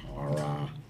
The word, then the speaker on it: Cara Dune: Wasn't it Cara Dune? – Cara. – Yeah, I like that pronunciation. – Cara. – That's correct.